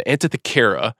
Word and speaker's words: Antikythera: We go back to the end of World War II to sort of introduce this dial, this titular item, the Antikythera, which is a...